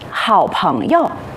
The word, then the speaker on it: 好朋友: 请大家注意这句话中的这个词语，在读的时候要读作轻声，请同学们和老师读一遍。好朋友。